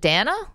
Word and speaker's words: Dana: Wait, so it's your name is D-A-N-A. Do you pronounce it Dana? – Dana.